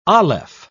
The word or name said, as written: aleph